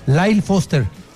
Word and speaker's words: Lyle Foster: Lyle Foster.